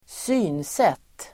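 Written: synsätt

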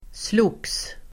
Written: slogs

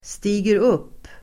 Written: stiger upp

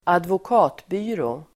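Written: advokatbyrå

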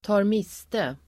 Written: tar miste

